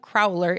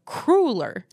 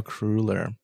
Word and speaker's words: cruller: It's not a crowler, it's a cruller. – A cruller. – It's of Dutch, German, Polish origin, says Kyle. – So... Take the word ruler, like the measuring thing, and put either a C or a K in front of it.